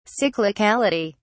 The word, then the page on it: cyclicality: English; British English